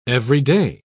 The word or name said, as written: everyday